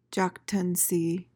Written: jactancy

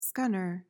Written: scunner

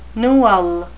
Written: newel

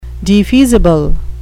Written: defeasible

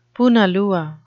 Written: punalua

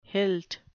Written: hilt

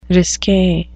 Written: risque

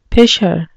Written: pisher

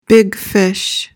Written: big fish

